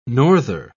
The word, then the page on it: norther: British English